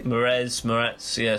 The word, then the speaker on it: Moretz: We've got here most underrated actor for him is Chloe Grace Moretz. I'm not quite sure how to say her last name. Is that right? – Moretz, yeah, something like that.